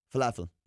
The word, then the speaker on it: falafel: Falafel. – Falafel. – What? – You don't, it's not a long A.